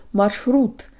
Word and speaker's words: маршрут: Hi guys, today you'll learn how to pronounce the word marshrut or route. Sha'er is tricky, but English has something similar. Shoulda, coulda, woulda. Shoulda, short for should have, sounds like the Russian sha'er combo, and if we practice flicking the tongue when we say it, we get really close.